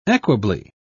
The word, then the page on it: equably: British English